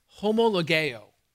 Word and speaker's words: homologeo: It's pronounced homologeo, and it's a compound word. It puts two words side by side. The word homo means the same. The word logeo means to speak.